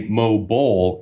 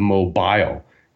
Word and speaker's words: mobile: You seem to say mobile instead of mobile, you know, like bile that is produced by the liver. Not bowl, as in the animal.